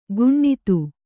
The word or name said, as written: ಗುಣ್ಣಿತ್ತು